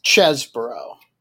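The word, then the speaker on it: Chesebro: Ken Cheese, or Chesbro, I should say. Now I know how to pronounce his name. – It's the cheese. – The cheese. – But it's Chesbro.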